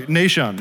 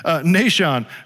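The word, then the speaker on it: Nahshon: So, I'm sorry, Nashon, that's the right one. I said that wrong, I meant it. Nashon, who is Salma's dad, is the brother-in-law of Aaron the priest.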